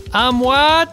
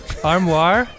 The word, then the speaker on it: armoire: Did I pronounce both words wrong? – Yes. – Armoire? Amour. Amour! I like it my way better. Armoire de Triangle.